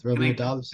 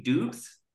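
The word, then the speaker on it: Doubs: Am I pronouncing it wrong, or is it Dobbs for both? – It's Dobbs for both. Romeo Dobbs. – They do Dubs? – You can say Dubs if you want to pronounce it incorrectly, but very on brand.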